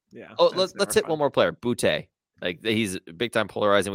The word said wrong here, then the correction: Boutte: Uh, actually, apparently, it's pronounced booty.